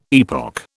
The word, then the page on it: epoch: British English